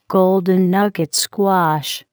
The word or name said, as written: golden nugget squash